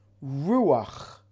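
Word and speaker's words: Ruach (רוח): If you spell revach, resh yud vav ches, and you take the yud out, It's spelled Resh Vav Ches. Revach, Resh Vav Ches, can be spelt, could be pronounced, I'm sorry, Ruach, Spirit.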